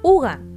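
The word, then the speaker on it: hygge: La pronunciación de hitch es uga y aunque no tiene una traducción exacta, esto hace alusión a acogedor o cálido.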